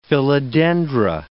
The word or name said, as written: philodendra